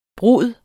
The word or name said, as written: brud